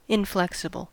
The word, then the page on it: inflexible: American English